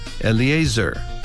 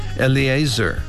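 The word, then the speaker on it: Eleazar: 이렇게 발음하시고 그 다음 엘르아살은 엘리에이저. 엘리에이저. 한 번 더요. 엘리에이저.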